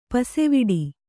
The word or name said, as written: ಪಸವಡು